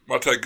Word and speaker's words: Montag: I think in one of the deleted scenes is Montag, is that how you say it? – Yeah. – Montag goes on a blind date and Faber is whispering in his ear what he has to say.